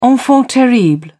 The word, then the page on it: enfant terrible: British English